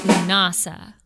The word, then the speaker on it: Lughnasadh: It is pronounced Lunasa. – Lunasa. – Not Lunasa. Lunasa. – Lunasa. – Maybe you can tell from the extra unnecessary spelling, but Lunasa, L-U-G-H-N-A-S-A-D-H.